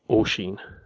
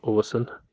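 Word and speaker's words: Oisín: I didn't know what name it was, and I downloaded the PDF of the book to find out that the spelling was O-I-S-I for the N, and that is pronounced O-Sheen. It's not pronounced O-Sin, it's O-Sheen, O-Sheen.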